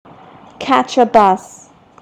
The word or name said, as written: catch a bus